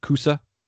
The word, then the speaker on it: Cusa: And it says, if I'm saying that right, Cusa? Yeah.